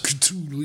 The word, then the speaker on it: Cthulhu: Øhm, Inden, inden vi runder ned, øh, du har ønsket at høre The Call of Cthulhu, tror jeg, det skal udtales. Cthulhu, ja.